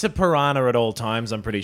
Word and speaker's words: piranha: I've heard you pronounce piranha. – Piranha. – Differently piranha. Every time. Piranha. – Yeah. – Piranha. – It's piranha. piranha sounds a bit posh, don't you think? – That is how it's pronounced.